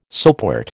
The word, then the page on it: soapwort: American English